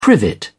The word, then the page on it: privet: English; British English